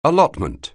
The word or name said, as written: allotment